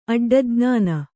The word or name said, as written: ಅಡ್ಡಜ್ಞಾನ